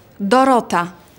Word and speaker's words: Dorota: It's Dorota. – How do you spell that? – D-O-R-O-T-A.